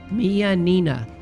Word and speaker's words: Minina: I believe you pronounce it Mianina. M-I-N-I-N-A. The white corn tortilla chip.